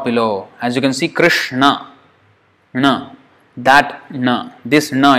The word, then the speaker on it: Krishna: When we say Krishna, this is the na that we are pronouncing, or supposed to pronounce. It's not Krishna, na. It's na, Krishna. So that's how it's pronounced.